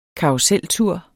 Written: karruseltur

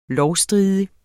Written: lovstridig

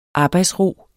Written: arbejdsro

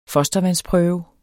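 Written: FOSTERVANDSPRØVE

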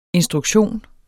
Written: instruktion

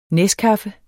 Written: neskaffe

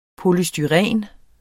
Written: polystyren

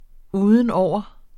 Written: udenover

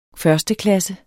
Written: førsteklasses

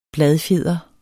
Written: bladfjeder

